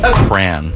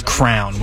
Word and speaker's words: crayon: How do you pronounce this word? – Cran. – Cran. – Cran. – Cran. – Crayon. – Cran. My wife says crown, which is ludicrous. That's cran. – Crown. – Crayon. Crayon.